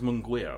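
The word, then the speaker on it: Munguía: It was Munguia, I was told it was how it's pronounced.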